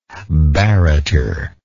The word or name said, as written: barretter